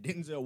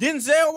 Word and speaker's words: Denzel: We figured out that Denzel Washington's name is pronounced Denzel, not Denzel.